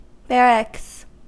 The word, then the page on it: barracks: American English